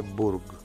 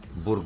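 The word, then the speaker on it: Bourg: που έψαχνα να βρουν χθες αν προφέρεται όντως Μπουργκ ή Μπουργκ. Αλλά έκατσα εγώ τους Γάλλους και συμφώνησαν είναι Μπουργκ. – Μπουργκ λένε οι Γάλλοι.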